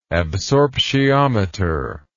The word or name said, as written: absorptiometric